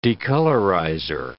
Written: decolorizer

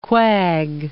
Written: quag

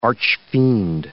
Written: archfiend

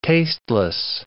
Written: tasteless

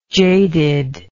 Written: jaded

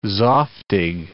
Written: zoftig

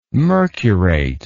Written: mercurate

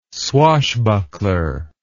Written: swashbuckler

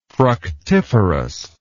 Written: fructiferous

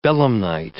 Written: belemnite

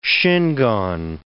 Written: Shingon

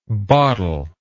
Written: bottlelike

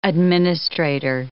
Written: administrator

